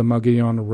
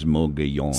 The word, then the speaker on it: Mogollon: M-O-G-O-L-L-O-N. – Yes, correct. – And pronunciation in Spanish is Mogollon. – Some say Mogollon and some say Mogollon.